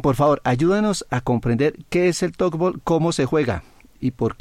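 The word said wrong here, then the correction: tchoukball: Bueno, primero entonces se pronuncia chútbol. – Ah, vea pues. – Bien. Cuando tengas la oportunidad, el balón cuando rebota contra una malla, contra un marco, hace ese sonido como ch. Entonces por eso se pronuncia y se denomina así, chútbol.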